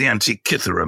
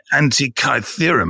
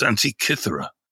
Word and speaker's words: Antikythera: Firstly, let me say, it's the Antikythera mechanism. It's not the Antikythera mechanism. That's how they pronounce it in the film, you know. They spent $295 million making it, and they couldn't bother to ask a Greek person how to pronounce it. It's pronounced Antikythera.